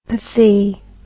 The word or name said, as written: pathy